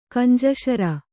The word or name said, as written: ಗುಂಜಿಸರಿ